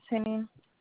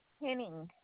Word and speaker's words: Henning: Everest Henning. – What is it? Your first and last name? – Everest Henning.